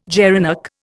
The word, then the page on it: gerenuk: Indian